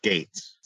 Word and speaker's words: Geiz: Well, his name is spelled G-E-I-Z, but it's pronounced Gates.